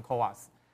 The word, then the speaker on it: CoWoS: 这个是 2.5D 的封装，哦，那这个 CoWoS 呢，指的就是呢这个晶片，哦，晶片呢要堆叠在晶圆上面，然后再堆叠在载板上面，哦，所以刚刚这个郑执行长提到的这个载板非常重要的这个角色，这个我们称为 Chip on Wafer on Substrate，所以就简称叫做 CoWoS，哦，所以念成 CoWoS。